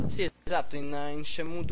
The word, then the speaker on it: Shenmue: E un altro dei grandi problemi che ci saranno questa sera sarà la pronuncia di Shenmue, che ci conviene mettere, cercare di capire subito all'inizio, prima di dire qualche oscenità. Io penso si dica Shenmue. – Così. Io ho sempre detto Shenmue.